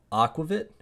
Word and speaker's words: aquavit: So if you're not a beer drinker and you're like me, you have one option and it's uh, it's called Aquavit, if I'm pronouncing that correctly.